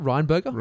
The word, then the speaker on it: Rheinberger: Rheinberger. – Yeah. It's only three syllables, same as McDonald.